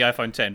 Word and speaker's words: iPhone X: iPhone what? – X10. – Oh, okay. Good. Okay. Just checking. – The police are back. The pronunciation police. I'm sorry. – I thought I'd invented a new phone or something, but you were talking about the iPhone 10.